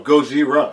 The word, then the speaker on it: Gojira: How do you say the actual Japanese word? – Oh, Gojira.